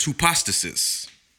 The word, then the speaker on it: hypostasis: And it's pronounced hypostasis. Hypostasis. It's spelled hypostasis. Hypostasis.